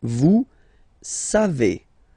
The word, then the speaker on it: vous savez: So classic ending for nous, O-N-S. You don't pronounce the final S. Nous savons. Nous savons. Okay. Then vous savez. Classic ending for vous, EZ, but then phonetically it's E.